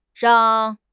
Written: rang4

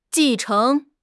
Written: 继承

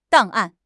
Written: 档案

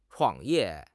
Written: 创业

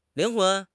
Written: 灵魂

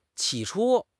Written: 起初

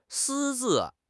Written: 私自

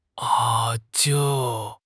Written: a5

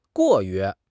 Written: 过于

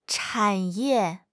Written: 产业

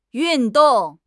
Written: yun4 dong4

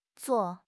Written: zuo4

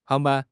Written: hang2 ban1

